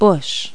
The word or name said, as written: bush